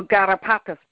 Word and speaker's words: garrapatas: Taz, did I butcher that? Por favor. – Yes, you did. – can you correctly pronounce that for us, please? Just so we know. – No garrapatas, por favor.